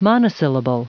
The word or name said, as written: monosyllable